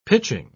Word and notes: pitching: British English

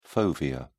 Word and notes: fovea: British English

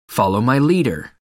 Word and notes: follow my leader: American English

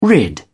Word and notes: rid: British English

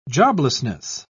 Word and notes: joblessness: British English

